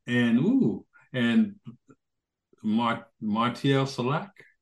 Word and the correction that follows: Solal: Oh, Martial Solal. – Solal, yeah, on piano and Moutin on bass, coming yesterday.